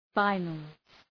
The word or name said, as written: finals